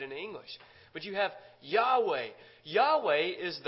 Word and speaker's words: Yahweh: Jehovah is actually a mispronunciation of the name Yahweh.